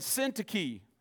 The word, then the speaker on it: Syntyche: Euodia and Syntyche. That's how you pronounce these names. Oh, what a lovely baby. What's her name? Syntyche.